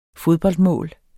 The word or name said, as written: fodboldmål